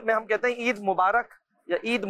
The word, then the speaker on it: مبارک: اچھا را کے نیچے زیر ہوتا ہے, رے کے نیچے ہے نا, اصل تلفظ ہے عید مبارک. – اچھا عید مبارک ہے.